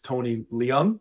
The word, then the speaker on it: Tony Leung: Another great element I want to add is, you mentioned him before, Micah, is Tony Leung. Is that how you pronounce his name? – Yeah.